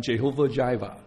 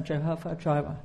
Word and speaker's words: Jehovah Jireh: 有没有人听过英文怎么读？ – 有时候英文的诗歌会唱 Jehovah Jireh。 – 有时候英文的诗歌会唱，刚才 Jehovah Jireh。 – 咁其实咧，希伯来文咧就系、是、如果我哋用翻 Jehovah 啦，耶和华啦，Jehovah Jireh。